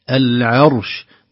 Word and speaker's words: العرش: العرش، نرقق العين ونفخم الراء ونسكنها ثم ننطق حرف الشين،